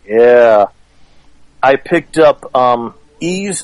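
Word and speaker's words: Ys: It's spelled Y-S, but it's pronounced Ease.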